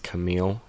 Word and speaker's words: Camille: Second one is in 1969, which I believe is pronounced Camille, C-A-M-I.